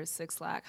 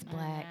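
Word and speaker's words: 6lack: For me, it's Black or Six Lack. How do you pronounce it? – It's Black.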